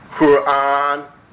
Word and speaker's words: Quran: You hear people saying Quran, Quran, but listen to me carefully and please change and encourage others that the correct pronunciation of this proclamation is Quran. Qur and then an, like A-N-N. Qur, and I rest briefly because there's a sukun on the ra, and I say an.